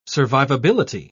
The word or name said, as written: survivability